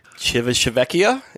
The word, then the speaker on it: Civitavecchia: Civitavecchia, is that it? – Yeah, there you go.